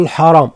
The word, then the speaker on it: الحرام: لفظة الحرام نفخم فيها كان الراء، لا نقول الحرام الحاء، الحرام.